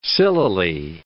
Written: sillily

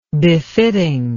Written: befittingness

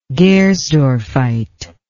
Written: gersdorffite

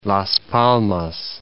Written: Las Palmas

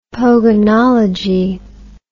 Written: pogonology

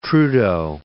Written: Trudeau

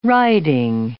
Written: writing

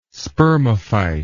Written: spermophyte

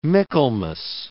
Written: Michaelmas

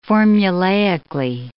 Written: formulaically

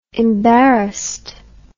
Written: embarrassed

